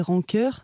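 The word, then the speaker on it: Rendcœur: Ouais, je m'appelle Rancœur. Ça s'écrit R-E-N-D, cœur. Je le précise parce que c'est pas que de la rancœur.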